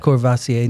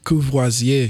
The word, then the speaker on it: Courvoisier: Science-wise, how's that, how's that Courvoisier now, man? – It's not. That's not how you say it. – Courvoisier. – Courvoisier. – Yeah, it's better.